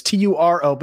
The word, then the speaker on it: Turo: Turo is the world's largest car sharing marketplace with Turo, and it's T U R O, but it is pronounced Turo.